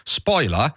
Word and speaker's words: spoiler: Spoiler, è comodo, veloce, adattabile facilmente alla fonetica italiana, anche se in inglese si pronuncia un po' diversamente da come lo pronunciamo noi. In inglese si dovrebbe dire spoiler, no.